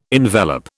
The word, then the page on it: envelop: British English